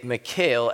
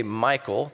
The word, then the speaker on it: Michal: Now, you're going to hear me say Michal as the name because in the Hebrew, that's kind of how it's pronounced. We often say, or we couldn't say Michael, that happens sometimes, but it's really Michal.